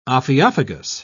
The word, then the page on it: ophiophagous: British English